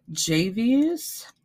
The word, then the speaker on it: Javyous: So we're gonna start with Javius, I believe is his name, because it's spelled JAVYOUS.